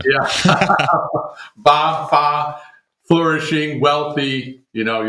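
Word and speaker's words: ba (八): Fa, ba, ba is how you say 8 in China.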